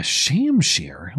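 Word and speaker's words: shamshir: The, uh, the sword is called a sham shear. Are you guys lying to me? – Oh, the second in- syllable is pronounced the the adjective sheer. Aladdin exaggerated the dimensions. – Uh, sham sheer.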